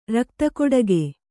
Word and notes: ರಕ್ತಕೊಡಗೆ: rakta koḍage

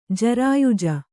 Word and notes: ಜರಾಯುಜ: jarāyuja

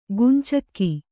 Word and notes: ಗುಂಚಕ್ಕಿ: gumcakki

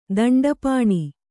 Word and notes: ದಂಡಪಾಣಿ: daṇḍa pāṇi

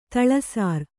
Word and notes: ತಳಸಾರ್: taḷa sār

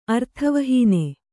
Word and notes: ಅರ್ಥವಹೀನೆ: kannada

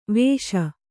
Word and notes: ವೇಷ: vēṣa

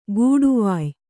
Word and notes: ಗೂಡುವಾಯ್: gūḍuvāye; kannada

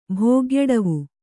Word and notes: ಭೋಗ್ಯಡವು: bhōgyaḍavu

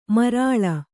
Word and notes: ಮಾರುಳಿ: Kannada

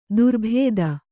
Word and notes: ದುರ್ಬುಧ: durbudha

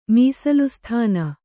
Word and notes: ಮೀಸಲುಸ್ಥಾನ: mīsalu sthāna

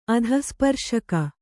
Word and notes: ಅಧಃಸ್ಪರ್ಶಕ: adhahsparśaka

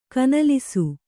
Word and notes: ಕನಲಿಸು: kanalisu

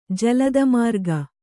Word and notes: ಜಲದಮಾರ್ಗ: Kannada

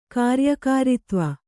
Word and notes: ಕಾರ್ಯಕಾರಿತ್ವ: kāryakāritva

